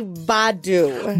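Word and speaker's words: Badoo: I'm going to say Badu. – Badu, you have to say it like that.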